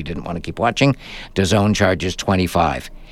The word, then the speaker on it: DAZN: DAZN is D-A-Z-N, pronounced DAZN, app or website.